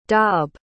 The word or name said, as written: dob